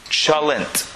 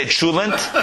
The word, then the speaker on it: cholent: C-H-O-L-E-N-T. Chalent. Chalent. Could be chul. Some people say chulent. Depends where you come from in Europe.